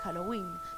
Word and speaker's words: Halloween: Bienvenidos y bienvenidas a este especial Halloween, como se dice en varios países desde el habla sajona, la pronunciación indicada es Halloween.